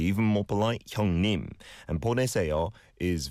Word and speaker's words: 보내세요: 보내세요. It's four syllables there.